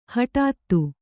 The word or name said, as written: ಹಟಾತ್ತು